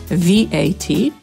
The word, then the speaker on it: VAT: Z innych skrótów, które często stosujemy, nawet po polsku, mamy VAT. Po polsku używamy tego skrótowca jako akronimu, czyli czytamy to, jakby było oddzielnym słowem. Po angielsku wymawiamy każdą literę oddzielnie. VAT.